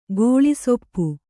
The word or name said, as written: ಕಾಳಿಸೊಪ್ಪು